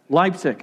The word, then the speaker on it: Leipzig: He and his wife and their family are going to Leipzig. Did I say it right? Leipzig. Ah, I had no idea.